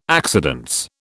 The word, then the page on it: accidence: British English